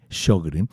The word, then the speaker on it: Sjögren: Mire, para nuestros oyentes, el Sjögren, que se escribe S-J-O-G-R-E-N, Sjögren,